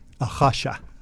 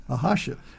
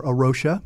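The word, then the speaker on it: A Rocha: But so this ministry, it's a capital A, and then a space, and then a capital R-O-C-H-A. So why don't you pronounce it just so everybody hears it real clearly? – I will say it in very terrible Portuguese. Arasha. – Arasha. Okay. – We say Arasha in English, or Arosha.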